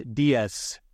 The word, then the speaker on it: dies: The word diet comes from the Latin word for day, pronounced dies.